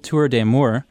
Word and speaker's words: Tour d'Amour: So right now at the time of this recording, we're in February and you're in the midst of your annual Tour d'Amour, which I'm sure I mispronounced. – Pretty good. – I think you may have actually been the first person to pronounce it correctly.